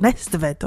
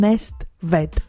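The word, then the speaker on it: Næstved: Και σήμερα θα πάμε στη Δανία, στο Melody Grand Prix του 2023 φυσικά, το οποίο μεταδόθηκε ζωντανά από την Arena Netvet. Ooh. Nestvet, όχι συγγνώμη, να το πω σωστά. Arena Nestvet.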